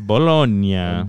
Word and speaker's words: Bologna: We got the team Bologna. – Bologna. – No, I looked at the pronunciation. It's Bologna. – Bologna. – There's an acento. There's an emphasis in the middle.